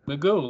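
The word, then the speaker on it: McGough: Magoo, like Mr. Magoo.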